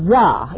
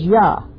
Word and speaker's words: Jah: Does any of your Bibles have his name is, and it's pronounced Yah. It's spelled J-A-H, but it's pronounced, I understand, as Yah.